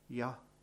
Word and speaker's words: Yahweh: Jews, to say that word, didn't need to really close their lips or use their tongues to say it, but the rabbis used to teach people how to say his name in hushed tones, and they used to say, Yahweh, Yahweh.